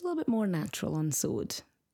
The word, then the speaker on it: saúde: Saúde. E saúde. Notice a little hat on top of the U, diacritic. – Mm-hmm. – That means you emphasize that particular syllable.